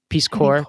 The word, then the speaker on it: Corps: Yeah, core, I'm hearing core, it like C-O-R-P-S-E. – Corpse. Is that how you say it? Core? Peace Corps? Peace Corps? – Yeah, man. Yeah.